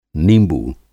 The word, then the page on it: निंबू: Hindi